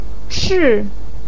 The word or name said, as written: chi4